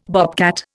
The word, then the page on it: bobcat: Indian